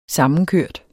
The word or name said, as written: sammenkørt